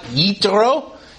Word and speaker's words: Yitro: This is the week of Parashat Yitro. Yitro is the Hebrew name or the way to pronounce Jethro.